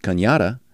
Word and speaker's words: Canada: A low of $325,000 on South Kanyada. I always pronounce it Kanyada, although it's spelled Canada, but I assume that's the way you pronounce it.